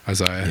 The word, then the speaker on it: Isaiah: I read a book recently, or I listened to an audiobook recently by an author from England who read it, and that's how she pronounced it, Isaiah.